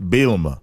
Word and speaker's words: Bielema: You brought up Coach, I want to say it right, Brett Bielema.